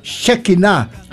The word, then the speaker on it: Shekinah: Ito, may Hebrew scholar na nagsabi sa akin na ang tamang pronunciation nito ay Shekinah Abalon.